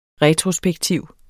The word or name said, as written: RETROSPEKTIVT